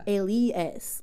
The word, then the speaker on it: Aliace: And then I like ended up just going by Ace because I ended up like getting in the music scene as Alias. Mm. But then people kept calling me Alice and I was just like, that's not how you say it.